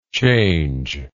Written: changedness

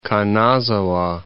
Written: Kanazawa

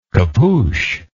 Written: capuched

